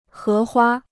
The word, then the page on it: 荷花: hé huā